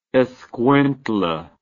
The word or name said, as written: Escuintla